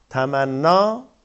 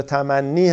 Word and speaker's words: تمنی: گفتم دیگه، تمنا یا تمنی هم تلفظ بکنید درسته، اماله میشه،